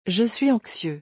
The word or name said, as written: Je suis anxieux